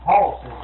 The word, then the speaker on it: hoss: Now you using the right pronunciation, I'm not. Horse is spelled H-O-S-S.